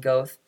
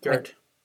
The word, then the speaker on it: Goeth: Goeth. – Gert.